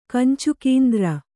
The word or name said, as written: ಕಂಚುಕೀಂದ್ರ